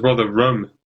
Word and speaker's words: Rotherham: thought it was rather rum. But that's how they pronounce it up north.